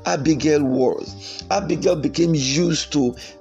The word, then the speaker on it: Abigail: Abigail, that's the Hebrew pronunciation.